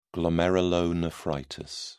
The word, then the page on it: glomerulonephritis: British English